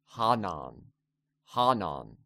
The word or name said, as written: Hanan